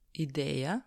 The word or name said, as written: ideja